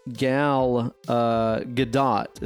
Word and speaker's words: Gal Gadot: Apparently, Gal Gadot is how you pronounce it, right? – Yeah, yeah.